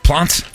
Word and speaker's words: Plante: The first goalie to wear a mask was Jacques Plante? – Plant. Yeah, the E is silent.